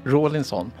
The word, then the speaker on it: Rawlinson: Det säger Michael Rawlins. Är det korrekt uttalat? – Rawlinson.